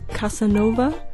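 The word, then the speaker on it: Casa Nova: The title of Casanova, it's a play. It's spelled C-A-S-A space N-O-V-A, new house. But it's pronounced Casanova, like a Don Juan type of figure.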